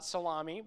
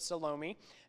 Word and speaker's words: Salome: Or if you look in later passages, you'll see that John's mother, who is often called Salome, I think is how you pronounce it, not Salami, but Salome,